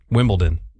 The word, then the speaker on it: Wimbledon: It's D-O-N. – Like Downton. – Okay, hold on. Say it correctly again. – Wimbledon.